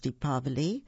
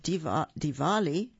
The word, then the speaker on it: Diwali: This book is about a celebration of the festival of Diwali, or could be pronounced Deepavali, or could be pronounced Diwali.